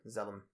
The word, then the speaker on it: Zalem: In the Wikipedia article, it's spelled Z-A-L-E-M. Zalem.